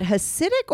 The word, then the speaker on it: Hasidic: Is it Hasidic or Hasidic? – It's Hasidic, but you're saying it right.